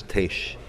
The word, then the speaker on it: Tish: Tish, I was getting lessons from Maxime in pronunciation of your name. It's Tish.